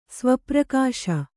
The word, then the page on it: ಸ್ವಪ್ರಕಾಶ: sva prakāśa